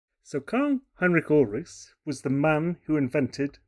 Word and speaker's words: Karl Heinrich Ulrichs: We are talking about Karl Heinrich Ulrichs. Am I saying that correctly? – You are, absolutely.